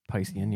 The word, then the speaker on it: Piscean: Yeah, I think that's how you say it, Piscean, yeah.